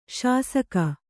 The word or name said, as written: ಶಾಸಕ